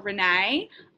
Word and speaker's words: Renae: Okay, my name is spelt Renee, R-E-N-A-E, Strugnell, S-T-R-U-G-N-E-L-L, Strugnell.